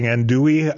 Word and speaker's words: andouille: How do you pronounce it? – Andouille. – Oh, like you pronounce the L? – Yes.